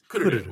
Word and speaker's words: Kururu: it's Kururu with the accent on the first syllable.